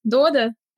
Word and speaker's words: Додо: А, мы сегодня в первую очередь говорим про твой опыт а, в Дода. Ты, кстати, ударение ставишь на первый слог, да, и внутри компании вы так говорите. Как правильно, скажи мне? – Ну, э, вообще, да, Дода, Э, птичка Дода.